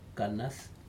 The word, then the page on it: kanad: Aneityum